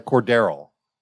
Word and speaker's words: Cordarrelle: But if we're talking about, I'm looking for another running back, like Cordarrelle. That's how you pronounce it. Cordarrelle Patterson. – That's how you pronounce it, Cordarrelle. – This is no. It's Cordarrelle. – Cordarrelle. Yeah. – No. Cordarrelle. That's why I said it right the first time.